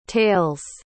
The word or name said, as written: tails